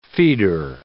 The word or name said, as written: feeder